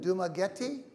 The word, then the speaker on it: Dumaguete: Is that Dumaguete? – Yes.